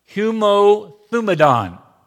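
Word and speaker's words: homothumadon: But there is a Greek word that is in this passage, and it is pronounced humothumadon. That's a great word, isn't it? Humothumadon.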